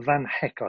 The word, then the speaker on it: van Hecke: Um, it is John Paul Van Hecke, I think it's mm. pronounced, isn't it? – Yeah.